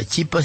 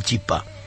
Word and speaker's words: chipá: Después de editar mi primer libro, dice la señora Miró, me di cuenta de que la chipa era un, o el chipá, algunos dicen el chipá, de Icoaimba erepa, pero no se dice la chipa, sino el chipá.